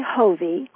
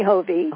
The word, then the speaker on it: Hovey: It's actually Hovey. – Hovey, it's like Bon Jovi, only Hovey.